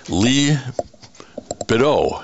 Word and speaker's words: Leigh Billeaud: We want to welcome to Veterans Radio today Navy veteran Leigh Billeaud. I'm sure I didn't get that right. Help me out on the French pronunciation of this Louisiana girl. – It's Leigh Billeaud. Everything's silent. It's very French.